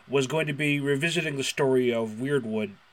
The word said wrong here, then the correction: Weirwood: Well, this is one of the last times I'm going to be able to mispronounce Weirwood, so why the hell not?